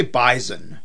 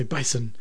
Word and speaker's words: bison: Yeah, we say bison. – With like a Z sound. Other people say bison.